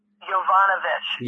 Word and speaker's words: Yovanovitch: Yovanovitch. – Yeah. And Yovanovich.